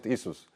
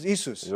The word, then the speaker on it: Isus: In Croatian, we pronounce it Isus. – In Croatian, Isus.